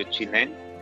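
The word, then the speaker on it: Zhineng: Chinmen se escribe Z-H-I-N-E-N-G y es espacio así escrito en letra, espacio chinen, que es la técnica de Qigong que yo practico.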